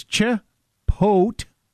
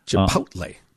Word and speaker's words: Chipotle: So it's Chipotle. That's the emphasis is on pote. – Chipotle.